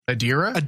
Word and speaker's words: Adira: Adria. That was a very metaphysical. – Who's Adria? – Or the character of, I keep saying her name wrong. Adira.